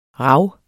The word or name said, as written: rav